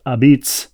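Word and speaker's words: apizza: The T's are just not there, just as they would never order pizza from Domino's instead of from Modern a Pizza, pronounced a beats.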